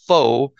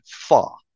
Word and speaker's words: pho: And then they have the pho, people say, but really you pronounce it pho.